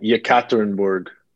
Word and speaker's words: Yekaterinburg: Uh, Yekaterinburg.